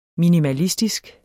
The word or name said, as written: minimalistisk